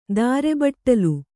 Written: ದಾರೆಬಟ್ಟಲು

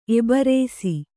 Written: ಎಬ್ರಾಸಿ

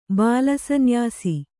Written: ಬಾಲಸನ್ಯಾಸಿ

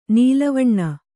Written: ನೆಲವೆಣ್ಣು

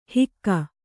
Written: ಹಿಕ್ಕೆ

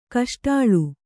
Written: ಕೇಸೊಡಲ

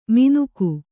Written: ಮಿನುಕು

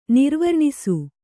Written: ನಿರ್ವರ್ಣಿಸು

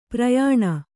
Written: ಪರಿಯಾಣ

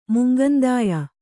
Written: ಮುಂಗಂದಾಯ

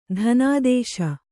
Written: ಧನಾದೇಶ

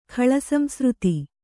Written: ಖಳಸಂಸೃತಿ

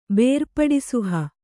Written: ಬೇರ್ಪಡಿಸುಹ

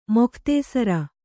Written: ಮುಕ್ತಾಸಾರ

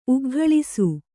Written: ಉಗ್ಗಳಿಸು

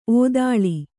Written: ಔತಳ

